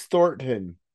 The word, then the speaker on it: Thornton: It's Thornton. The N's like quick. Thornton. So, like it's there, but it's quick.